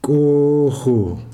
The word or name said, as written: koho